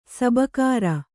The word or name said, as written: ಸಬುಕಾರ